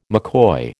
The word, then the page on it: McCoy: American English